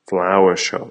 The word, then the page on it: flower shop: American English